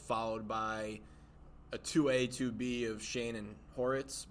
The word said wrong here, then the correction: Ortiz: But if you were John Mara. – Ortiz. – Yeah, I butchered that.